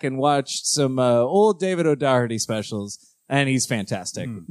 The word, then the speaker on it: O'Doherty: My bright spot is the comedian David O'Doherty, Dan. Mm. – You might have overpronounced that name. – David O'Doherty, Dan. You pronounce it right.